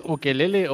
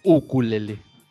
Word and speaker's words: ukulele: ¿Es ukelele o ukulele? – Uku, con U. – Sí, porque es la pronunciación hawaiana.